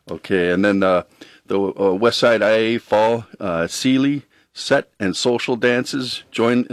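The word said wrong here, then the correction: céilí: Did I pronounce that right? Sealy? The Sealy? West Side Fall Sealy? – Kaylee.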